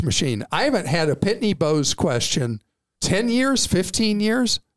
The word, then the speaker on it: Pitney Bowes: We currently have a Pitney Bowes, is that how you say it? – Pitney Bowes.